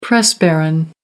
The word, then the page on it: press baron: American English